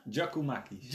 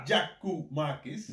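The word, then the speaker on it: Giakoumakis: Gio Kaumakis. Zeg ik het goed? – Ja, ik weet niet hoe vaak die naam genoemd zijn elke keer verkeerd. – Gio Kaumakis. Ja? – Giacomakis. – Giacomakis. – Ja.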